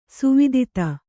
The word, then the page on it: ಸ್ವಧಿತಿ: svadhiti